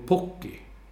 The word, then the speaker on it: Pukki: I alla fall så uttalade ju jag då Pukki fel. Han sa U blir O på finska. Så då ska man säga Teemu Pukki.